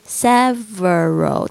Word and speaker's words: several: We have several，大家稍稍微注意一下 v 的发音。 several 这个 v 的声音呢，是咬住这个下，轻轻的咬住下嘴唇发 v v 的声音。